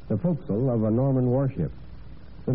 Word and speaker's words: forecastle: In the sometimes odd way of words, the term forecastle, which we pronounce forecastle, has survived, while aftercastle has gone into virtual oblivion.